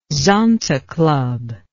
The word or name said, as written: zonta club